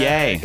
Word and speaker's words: Ye: She's saying his name wrong. – Yay. – Oh, I mean, Ye. His name is Ye. – His name's Ye.